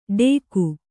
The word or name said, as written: ಡೇಗು